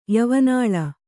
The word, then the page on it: ಯವನಾಳ: yavanāḷa